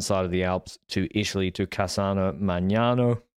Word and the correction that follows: Magnago: Magnago is how you say it, actually.